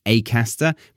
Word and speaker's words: Acaster: My dialect would encourage me to say Acaster, but he is from the Midlands, and he pronounces his name with an A sound, not an R.